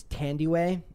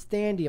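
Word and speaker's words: Thandiwe: I also, the other day, I said Tandaway Newton. It's Tandyway, but somebody else was saying it's Thandy. I'm like, it's not Thandy. The H is silent,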